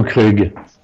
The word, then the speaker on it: Krug: La pronuncia punto... La chiediamo, noi lo chiamiamo Krug a Roma, non lo so qual è il nome. – Io dico Krug.